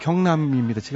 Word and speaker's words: 경남: 경날 이, 경날은 없죠요 경남입니다. 제가 나이가 먹어가지고 발음도 또 시원찮고.